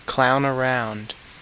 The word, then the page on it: apa: /ˈɑːˌpa/